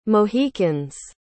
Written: mohicans

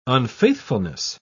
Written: unfaithfulness

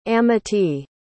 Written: amitee

